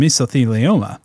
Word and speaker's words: mesothelioma: And then a narrator comes in and they say, do you suffer from mesothelioma? – Yeah. Hey, nice pronunciation.